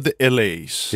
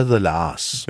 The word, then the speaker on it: The La's: Er det The Lars eller The LA's? – Det er The Lars.